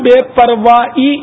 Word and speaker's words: بے پرواہی: عام طور پہ لاپرواہی آخر میں ہا لاپرواہی بولتے ہیں تو اصل تلفظ جو ہے اس کا پرواہ اس کو پرواہ نہیں ہے پرواہ نہیں ہے یہ ہا یہ اصل تلفظ میں ہا نہیں ہے اور پرواہی نہیں پرواہی حمزہ اور یا پرواہی اور لا پرواہی لا عربی لفظ ہے یہاں لا کی ترکیب ہے نہیں یہ اس کی ترکیب ہے بے پرواہی اصل تلفظ ہے